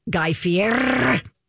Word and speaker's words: Guy Fieri: Guy Fieri. That's how you pronounce Guy Fieri, by the way.